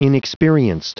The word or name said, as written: inexperienced